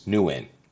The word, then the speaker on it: Nguyen: So his last name is pronounced Nguyen. If you're looking at his last name, it actually looks like it has more letters and syllables than that.